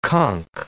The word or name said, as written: conch